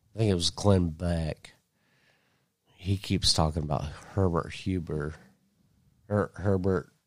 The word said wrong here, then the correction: Hoover: Yeah, did I say that right? – Hoover, yeah.